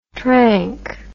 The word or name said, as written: tranq